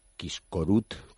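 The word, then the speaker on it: Kiskörút: El nombre húngaro pues es Quiscorut, como se pronuncie, que soy Perfecto, perfecto, lo has hecho perfecto.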